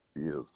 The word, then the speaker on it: Beuth: Gary Beuth, Beuth.